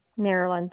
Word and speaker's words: Marilyn: Marilyn Buckman, B-U-C-K-M-A-N. – You said Mary? – Marilyn, M-A-R-I-L-Y-N.